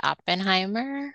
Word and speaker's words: Oppenheimer: We went to see Oppenheimer.